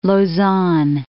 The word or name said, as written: Lausanne